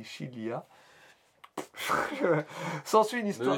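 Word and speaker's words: Chilia (χίλια): Le nom du groupe, directement lié au village qui les a vus naître, 1000, se prononce Chilia en grec.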